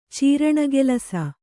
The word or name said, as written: ಚೀರಣೆಗೆಲಸ